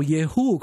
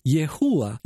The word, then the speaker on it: Yehú: Y bueno, se puede pronunciar como Yehú, como dijimos, o si tiene una Hei al final, Yehúa.